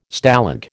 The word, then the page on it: Stalag: American English